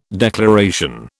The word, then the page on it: declaration: British English